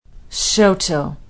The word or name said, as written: Shoto